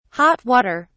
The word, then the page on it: hot water: British English